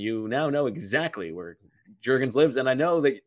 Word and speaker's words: Jurgens: There was questions on draft night about the pronunciation of his name, whether it's Jergens or Jergens. Ooh. It is definitively Jergens.